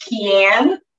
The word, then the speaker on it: Kian: But first off, my name is pronounced Kian Mejia. It's my married name. My husband's Colombian, so it's Spanish.